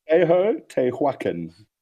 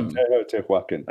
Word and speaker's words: Teotihuacan: Teotihuacan. Well, how do you say it? – Tehotihuacan. – Teotihuacan. – Yeah, Tehotihuacan.